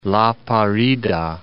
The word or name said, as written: La Parida